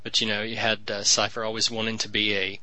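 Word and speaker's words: Seifer: Cipher sounds right. It rhymes with Kiefer.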